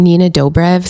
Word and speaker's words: Nina Dobrev: Yeah, I think because he was on with like Nina Dobrev. Is that how you say it? – Yes.